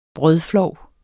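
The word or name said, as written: brødflov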